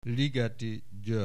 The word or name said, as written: Ligeti Gyoergy